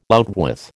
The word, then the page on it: outwith: American English